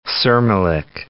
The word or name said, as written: Sirmilik National Park